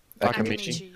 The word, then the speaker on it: Akimichi: Choji Akimchi? – Akimichi. – Akimichi. Yeah. Um, I missed a syllable.